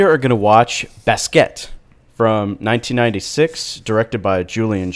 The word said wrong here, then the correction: Basquiat: Yeah, it's Basquiat.